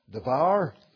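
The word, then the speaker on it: devar (דבר): The Hebrew for that word is actually pronounced devar.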